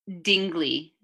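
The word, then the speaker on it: Dingli: Is it the Dingle Heritage Trail? – It's actually Dingley.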